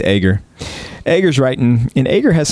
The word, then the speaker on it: Agur: Agur is how the Hebrew in my Logos program pronounces it.